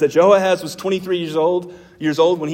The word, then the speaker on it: Jehoahaz: Before Jehoiakim, there's a guy named Jehoahaz.